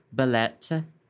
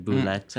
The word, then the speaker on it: belette: His name is Belette. – Belette. – Which is French for weasel, and I'm saying it wrong. Apparently, I say it like meatball, like boulette. – Boulette. – Like meatball. He's my little meatball, too.